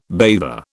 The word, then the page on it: bather: British English